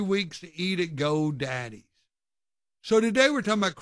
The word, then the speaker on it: Geaux: And it's spelled like a Cajun would spell go, G-E-A-U-X, Go Daddy's.